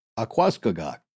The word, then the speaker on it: Aquascogoc: In this case, it's spelled A Q U A, so like aqua, mm-hmm. and then C O G O C. So to me, Aquascogoc.